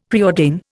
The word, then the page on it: preordain: Indian